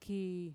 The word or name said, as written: kii